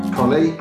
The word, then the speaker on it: Colley: Today, I am joined by Rachael Colley.